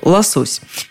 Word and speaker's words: лосось: Вообще-то и словарь ударений Агеенко и Зарвы, и все прочие словари, конечно же, приводят другое ударение, которое известно большинству – лосось.